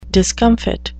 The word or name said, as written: discomfit